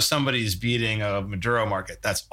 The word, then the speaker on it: Maduro: And I mean, we saw it with the Mandora, like, am I pronouncing that right? With the Venezuelan, it was instead of what? – Maduro. – Maduro, sorry.